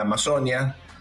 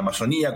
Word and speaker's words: Amazonía: la cuestión de la Amazonia, o la Amazonía, como dicen ellos en el acento en, la I.